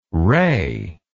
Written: rey

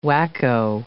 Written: whacko

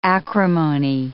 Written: acrimony

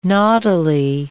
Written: naughtily

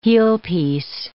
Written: heelpiece

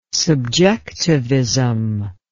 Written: subjectivism

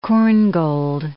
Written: Korngold

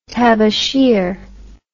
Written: tabasheer

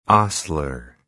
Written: ostler

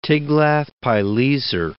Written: Tiglath-pileser III

